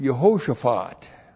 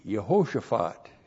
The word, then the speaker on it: Yehoshaphat: Jehoshaphat is the Hebrew word Yehoshaphat. That's what how it looks like in the Hebrew, and it's Y, Shava, H, long O, S, H, A, P, H, A, T, Yehoshaphat.